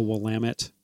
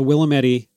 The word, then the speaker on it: Willamette: like the Willamette, not the Willamette, the Willamette.